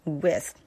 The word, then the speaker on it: with: 所以这一句就是 Sometimes the sky is bright blue, dotted with dotted with 在这里呢也是有一个弱读 with 这个词注意一下，这个 th 的这个结尾千万千万一定要咬舌不要 with 不要发成是 s 的这个音，而且这个中间这个小的这个 i 它发音呢是 i 它不是 e 它是 i 有点像 E，有点像 A， with